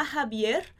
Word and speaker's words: Javier: A, ha, bi, er, esse R, esse R, a gente prolonga um pouquinho. A Javier le gustan los jalapeños en...